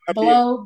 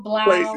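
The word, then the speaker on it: Blough: Blow, Blau. – But that man's name is Blau, not Blow. – But it's like O-U-H, and I thought of like dough, so I put Blow, you know? – Hey, Black A.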